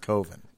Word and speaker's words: coven: A coven. – Oh, I think Joe was slightly ahead. – Although I saw a movie once where they corrected that mispronunciation quite often. That it actually is correctly pronounced coven.